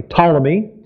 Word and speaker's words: Ptolemy: Actually, the P is better pronounced in a silent way, Ptolemy.